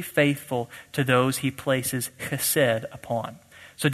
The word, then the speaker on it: Chesed: Chesed is how that's pronounced.